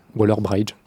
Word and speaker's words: Waller-Bridge: La réponse c'est Phoebe Waller-Bridge. voilà, vous devriez le savoir. – C'est vraiment comme ça que ça se prononce Je ne sais pas. Waller-Bridge je vois pas comment ça pourrait se prononcer autrement.